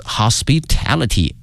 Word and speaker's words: hospitality: Home sharing always encourage hosts to show their hospitality，哎，他们的好客的这么一个文化哈。那么这个词 hospitality H-O-S-P-I-T-A-L-I-T-Y hospitality，同学们千万哈不要把这个词和 hospital 医院弄混了。 Hospitality 它的发音也是不一样的。